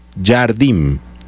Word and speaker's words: Jardim: que es dirigido por Leonardo Jardim.